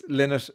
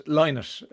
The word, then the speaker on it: Lynott: I know Philo's two daughters, and that's how they pronounce Lynott. Lynott, like that man there, says Maureen in Mohill. At long last, Philip Lynott's name is being pronounced properly.